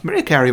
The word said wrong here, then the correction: Mariah: Did you just pronounce her Maria Carey? – I did, yes. – Oh, come on. – I was... very 50-50 on it. I didn't know. – It's Mariah Carey.